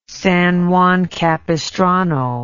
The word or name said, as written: San Juan Capistrano